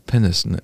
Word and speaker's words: Peniston: Peniston.